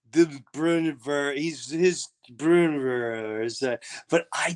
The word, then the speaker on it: Breuniverse: I went, "Just think of brewer and universe. Breuniverse. – Sound it out.